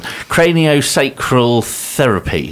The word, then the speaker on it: craniosacral therapy: Craniosacral therapy.